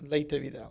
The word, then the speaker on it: Leyte Vidal: Yep, Leyte Vidal.